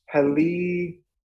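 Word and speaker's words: Helligkilde: But Marcus Helikidi. That's how it goes. – Yeah, we've had success with the pronunciation of it that way.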